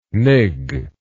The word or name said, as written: nig